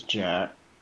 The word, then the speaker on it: Jack: Yeah, this is Jack. – You said Jack? – Yes, Pinkerton. – Okay, so J-A-C-K? – Mm-hmm.